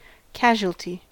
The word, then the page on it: casualty: American English